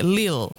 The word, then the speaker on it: Lille: Arsenal wanawakaribisha Lille na Manchester City wakiwaalika mabingwa watetezi PSG. Is it Lille or Lille? How do you say? – Lille. – Okay, Lille. Lille. From France.